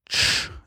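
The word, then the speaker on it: ĉ: then the C with the roof is ch, like in Czech rap.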